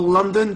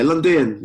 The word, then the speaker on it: Lundén: I've got a guest on the show, international, my first Scandinavian guest, Carl London. Did I say that correct, Carl? – More or less. The English pronunciation, pretty much London. In Swedish, Lundén. – Okay, Lundén, yes? – Yeah.